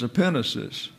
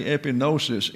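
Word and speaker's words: epinesis (ἐπίγνωσις): In other words, EPI is the concept followed by a gamma. That there, because it is an associated with the new that follows, is also pronounced as if it were new or an N. And so the word is epinesis.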